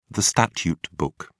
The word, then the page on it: statute book: British English